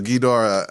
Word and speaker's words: Ghidorah: So Ghidorah, I believe, is technically correct from the Japanese pronunciation, but yeah.